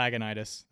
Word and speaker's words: Lagunitas: Listener, I usually call it Lagunitas.